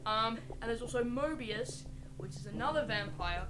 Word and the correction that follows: Morbius: Morbius. It's not Owen Wilson Mobius.